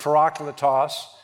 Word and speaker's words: parakletos: is the Greek word parakletos, or some pronounce it parakletos,